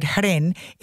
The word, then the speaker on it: hren: In Slovenian, hren is pronounced with a short e, and the word has been part of the Slovenian language for centuries. The Slovenian Ethnological Dictionary records the word hren